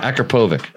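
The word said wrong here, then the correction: Akrapovič: That's a hard one. No, it's Akrapovich. – Akrapovich.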